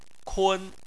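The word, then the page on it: kun: tone 1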